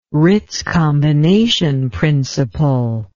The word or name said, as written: Ritz combination principle